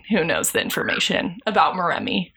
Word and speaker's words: Moremi: Also, I'm adding Moremi, and they pronounce it Moremi.